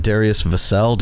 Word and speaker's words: Darius Vassell: How do you say it? Darius Vassell? – Yes, sir. I think you got it right. With the first one.